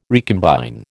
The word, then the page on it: recombine: American English